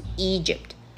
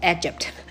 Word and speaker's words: Egypt: 好，再来一个字呢，你可能会念错，就是 Egypt，埃及这个字，很多人会把它念成 Egypt，好是 Egypt，Egypt。